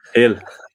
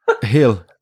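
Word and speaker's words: Gil: And you have gone for Brian Gill from Hill. – Hill. Gill, same thing. It's a G.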